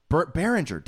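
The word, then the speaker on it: Berenger: Veteran catcher Jake Taylor, the Tom Berger... Berringer, damn it. I can't pronounce his last name.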